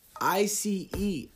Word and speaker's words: ICE: ISIS, I C E, ISIS. Am I saying that right? Oh shit, I'm saying no. Oh. oh my fucking gosh! All right, guys, shoot me now. Pew. Ice. I've been saying ISIS for fucking what reason and shit.